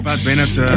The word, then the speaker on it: Pat Benatar: Así Pat Benatar.